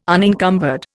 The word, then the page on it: unencumbered: Indian